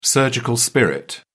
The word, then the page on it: surgical spirit: British English